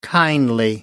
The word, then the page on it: kindly: American English